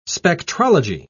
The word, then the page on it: spectrology: British English